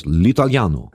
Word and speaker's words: L'Italiano: Aber toll, das Ding heißt, ja, wie spricht man es aus? L'Italiano. – Si, si.